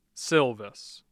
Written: Silvis

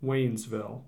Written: Waynesville

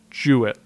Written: Jewett

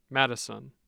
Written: Madison

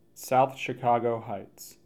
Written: South Chicago Heights

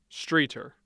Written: Streator

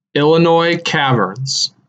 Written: Illinois Caverns

